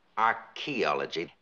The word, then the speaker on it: archaeology: Archaeology. The H is silent.